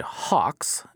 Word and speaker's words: Heux: It's by a 21-year-old music producer and DJ from Norway named Hux. It's spelled H-E-U-X, but that's the proper pronunciation there.